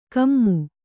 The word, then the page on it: ಕೊಮೆ: kannada